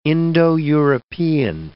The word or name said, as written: Indo-European